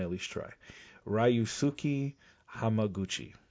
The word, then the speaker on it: Hamaguchi: I think you nailed the last name, Mr. Hamaguchi. – Or maybe not Ryu, but maybe Ryu. Ryusuke. – Ryusuke Hamaguchi.